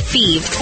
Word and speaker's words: Fives: Thebes is spelled F-I-V-E-S, like the number, but pronounced Thebes.